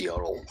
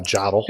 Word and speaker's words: Jodl: how I would pronounce J O D L is yodel. It is yodel.